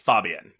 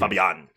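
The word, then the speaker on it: Fabián: so you're putting the accent on the first A, Fabian, but it's mm-hmm. Fabian because the accent's oh on the second A.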